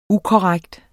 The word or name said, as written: ukorrekt